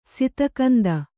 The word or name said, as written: ಸತುಗುಂದು